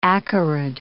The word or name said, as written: acarid